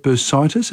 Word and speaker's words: bursitis: Is that bursitis? Have I said that correctly? Yep.